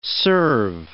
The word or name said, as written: serve